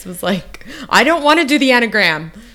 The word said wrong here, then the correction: Enneagram: I don't even think I'm saying it right. – But like, also, that's probably, I bet if you took what, like, the Myers-Briggs or the, yeah, I'd rather hear how you say it right. Enneagram, whatever. – Oh, Enneagram. That's it. Thank you.